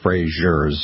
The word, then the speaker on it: Fraser: So if you want to Google Elizabeth Fraser with an S in Elizabeth and an S in Fraser, not a Z. – Right, because there were others with a similar name, Elizabeth Frasers, but Elizabeth Fraser, if you want to pronounce it.